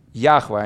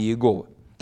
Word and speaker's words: Яхве: И сегодня большинство богослов сходится на том, что наверняка это произносится как Яхва, а не Иегова.